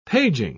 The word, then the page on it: paging: British English